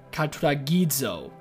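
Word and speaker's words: katuragizo (καταρτίζω): The Greek word for restore is the word katuragita. Crap, I didn't pronounce it right. It's katuragizo. Katuragizo.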